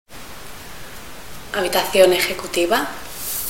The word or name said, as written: executive room